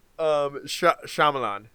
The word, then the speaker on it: Shyamalan: No, it's Shlama. Oh no, now I'm saying it incorrectly. Damn it, Zach. Shyamalan.